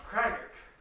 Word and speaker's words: Cragach: And then as you follow it down to the next circle, you see one around Craggach. – How do you spell it? – C-R-A-G-A-C-H.